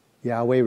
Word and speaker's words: Yahweh: Yahweh requires you to open up your throat, to drop your jaw, and exhale without restriction.